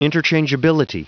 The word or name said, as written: interchangeability